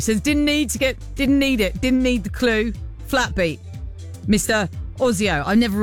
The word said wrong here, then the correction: Oizo: Oizo? It's not Ozio, is it? It's the O-I-Z-O. Oizo? Oizo?